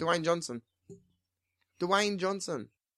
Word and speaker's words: Dwayne: Dwayne. Like Wayne, but with a D at the start. – Yeah, Dwayne. Dwayne Johnson. – Dwayne. Where's Dwayne? – Shut up! – One syllable. Dwayne. – Dwayne.